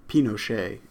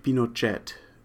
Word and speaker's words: Pinochet: Firstly, I've heard the name of this figure pronounced both Pinochet in the French fashion and Pinochet in the Spanish way. Now, it may be more correct to say Pinochet, but I think it's more common, at least in the United States, to pronounce it Pinochet, so that's how I'm going to do it here.